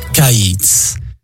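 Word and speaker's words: kaïtz (קיץ): Le mot pour dire été en hébreu est kaïtz. Kaïtz qui s'écrit de la manière suivante, kof, yod, tsadé sofit, le long, tsadé de fin de mot, kaïtz.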